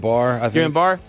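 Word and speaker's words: Guillain-Barré: Is it Guillain-Barre syndrome? – Guillain-Barre.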